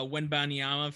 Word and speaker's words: Wembanyama: Uh, Wembanyama for next year.